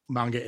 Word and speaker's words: manga: I think there was a heavy manga influence on the Scott Pilgrim books. – Manga. Okay. I've been pronouncing that wrong for 30 years.